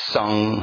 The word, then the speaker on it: Sang: Now, you notice in the pronounce I say Sung Hung Srang. Now the G is not really pronounced. It's like a nasal. So Sung, not Sung. You don't say it like that, yeah?